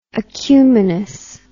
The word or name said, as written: acuminous